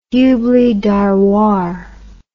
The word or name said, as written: Hubli Dharwar